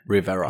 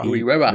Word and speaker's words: Rivera: Rivera. Rivera.